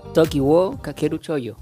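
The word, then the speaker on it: Toki wo Kakeru Shōjo: El día de hoy nos trae una recomendación de Tokiwo Kakeru Shoujo. ¿Lo dije bien? – Toki wo Kakeru Choyo. Era Choyo.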